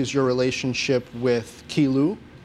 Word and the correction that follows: Qi Lu: Qi Lu, yeah. Qi Lu. I, yeah always pronounce that wrong.